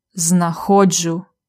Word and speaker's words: знаходжу: Наголос на О. Я знаходжу.